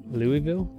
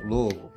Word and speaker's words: Louisville: Louisville? – No. – I don't know. I'm from the north. – That's close enough. Louisville. – Say it like you have a box of marbles in your mouth.